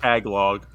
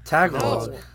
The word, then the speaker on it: Tagalog: Did I say it wrong? – It's a Tagalog. – Yeah, is that what it's called? Tagalog. – Tagalog. – Yes, yeah, Tagalog.